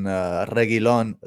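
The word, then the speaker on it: Reguilón: Yeah, if we're saying like nobody's going to keep a clean sheet, then Reguilon, that the U is silent, by the way.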